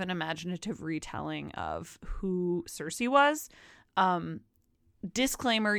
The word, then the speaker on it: Circe: Number two, Cersei by Madeline Miller. It is not spelled like Game of Thrones Cersei. It's spelled C-I-R-C-E, which I assume the Game of Thrones Cersei is adapted from, because Cersei is a Greek, she's a mythological Greek goddess.